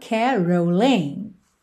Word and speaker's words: caroling: Later we'll have some pumpkin pie and we'll do some caroling. A sílaba tônica de caroling é a primeira, mas aqui a gente tem um alongamento dessa última sílaba, então a gente tem caroling, caroling.